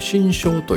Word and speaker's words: 心象: あのね、えー、グッドウェブスの考えの中に、えー、ていうのかな悩みの要因というのは基本的に僕らが頭で作り出す、えー、心の像と書いてね心象と読むんですけども、